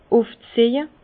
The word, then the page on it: ufzieje: Bas-Rhin; Herrlisheim